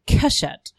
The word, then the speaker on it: Keshet: And if you put those three together, you pronounce them Keshet, which means bow.